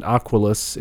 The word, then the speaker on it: Aquilus: Uh, I'm pronouncing it Aquilus.